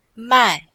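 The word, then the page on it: mai4: tone 4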